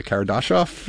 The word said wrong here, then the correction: Kardashev: Hold on, the Kardashev? – Kardashev, thank you.